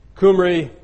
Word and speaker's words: Kumri: Kumri is the Assyrian way of saying Omri, the K.